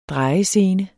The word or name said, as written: drejescene